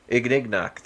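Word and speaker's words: Ignignokt: Ignignock, thank you.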